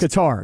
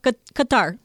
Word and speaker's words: Qatar: It's like guitar. Qatar. There's like an accent on it. Qatar. And you're supposed to roll the R at the end.